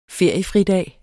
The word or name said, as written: feriefridag